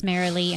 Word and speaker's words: Marilee: Yes, it is Marliee, and it is Bramhall.